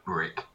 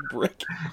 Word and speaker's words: Bryk: Greg Brick. – Greg Brick. – It's spelt... B-R-Y-K. It's still that's Greg fake. Brick.